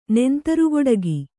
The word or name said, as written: ನೆಂತರುಗೊಡಗಿ